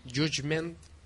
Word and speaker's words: Judgment: Judgment, ¿lo he pronunciado bien? – Muy bien, muy Pero, bien.